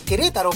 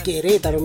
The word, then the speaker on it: Querétaro: Ellos están desde Querétaro, nos están escuchando. Querétaro, perdón, tiene acento en la segunda E. Querétaro, me corrijo yo mismo.